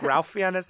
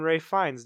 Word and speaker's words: Fiennes: In the Lego Batman movie, Ralph Fiennes, whose name is spelled Ralph Fiennes, and every time I read it, I think Ralph Fiennes, and I'm so sorry. I know it's Ralph Fiennes.